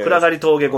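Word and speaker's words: 暗峠: そう、先ほどシトロエンさんもね、おっしゃってた暗がり峠越えです。 – ああ、これ暗い峠と書いてね、暗がり峠って読むんですよね。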